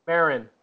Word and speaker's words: Maron: Maron.